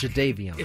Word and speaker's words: Jadeveon: Jadeveon Clowney. I messed up his name earlier. – Oh, did you? Well. – Apparently, I was calling him Javon. – If we had to go back. – Jadeveon.